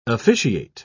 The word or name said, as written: officiate